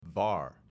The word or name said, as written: VAR